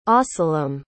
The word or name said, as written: ahsalom